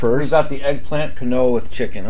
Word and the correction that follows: quinoa: Quinoa quinoa.